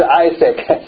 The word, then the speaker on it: Isaac: 伊萨克瓦茨呢 Isaac 哦，对对，谢谢谢谢，这个发音啊 Isaac 不应该是 Isaac，那我们这个中文翻成以撒啊，这个中文翻成是以撒，不是雅各，亚伯拉罕以撒雅各对吧，但是英文发音是 Isaac，谢谢问问。